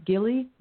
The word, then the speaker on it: Gilli: So I would like to introduce you to Gilli Messer.